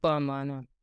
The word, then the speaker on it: CoAmana: What inspired the name CoAmana, if I'm pronouncing it right? – Yes, CoAmana.